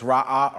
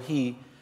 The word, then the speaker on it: Ra'ah: Jehovah Ra'ah, you got to separate. It's Ra'ah or Rohi.